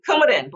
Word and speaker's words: Coumadin: I guess for you, the first one was Coumadin. Is that how you pronounce it? – Coumadin, blood thinner.